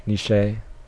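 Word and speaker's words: Nitzsche: I believe it was written by, I just looked this up, I don't know if I'm pronouncing it right, I'm probably butchering it, Jack Nietzsche. I think that's how it's pronounced. – Okay. Niche, maybe? – Nietzsche. – Okay, good.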